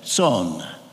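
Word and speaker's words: Țon: His name, Joseph Ton, spelled T-O-N, in Romanian, pronounced T-S-O-N, Son.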